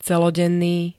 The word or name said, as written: celodenný